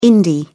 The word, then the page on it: indie: British English